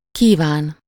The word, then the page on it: désirer: France; /de.zi.ʁe/